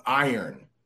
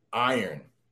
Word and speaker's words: iron: You can't sound this out. It's more like I earn. Like this, I earn. Or like you earn money, but iron. That's how you say it, iron.